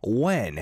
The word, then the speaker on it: WH3N: The first song was 오늘도 밤이야, It's Night Again by OOO, and the second song was 약속, translates to promise by When.